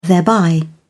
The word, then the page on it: thereby: British English